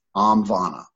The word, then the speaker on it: Omvana: There's another one called Amvana. I'm going to spell that phonetically. I always do it. It's Amvana, which is Oscar Mike Victor Alpha November Alpha. Omvana, Omvana.